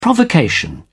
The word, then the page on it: provocation: British English